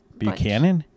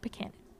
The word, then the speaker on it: Buchanan: Spell it. It's like Butch and Book and then Buck. – Buchanan. – Buchanan. Okay. I've never had to say it out loud before. Buchanan.